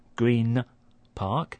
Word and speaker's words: Green Park: So the N sound at the end of green starts to sound like a M. So instead of Green Park, it's Green Park.